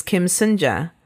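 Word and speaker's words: Soon-ja: Our antagonist, our bad guy, is Kim Sunja. And this is Kim Sunja. – Kim Sunja and Kim Sunja. – Ours is spelled S-U-N. And this victim is S-O-O-N.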